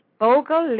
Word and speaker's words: Bogle: B-O-G-L-E. – B-U-G-L-E. Oh, Bugle. – O-G-L-E.